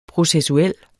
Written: processuel

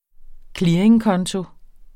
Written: clearingkonto

